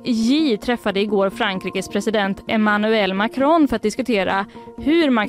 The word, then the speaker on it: Emmanuel Macron: Emmanuel Macron, vilket gött uttal du hade på den.